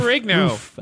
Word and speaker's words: Ferrigno: Lou Ferrigno.